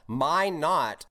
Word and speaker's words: Minot: So going back to your earlier solo, let's see if I pronounce this right. It's called Minot, North Dakota. That's where you were born? – That's correct. Yep.